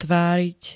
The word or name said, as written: tváriť